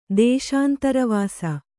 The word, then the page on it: ದೇಶಾಂತರವಾಸ: dēśāntara vāsa